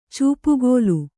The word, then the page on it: ಚೂಪುಗೋಲು: kannada